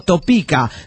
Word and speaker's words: Topeka: Dice, la palabra Topeka se pronuncia en castellano como Topeka.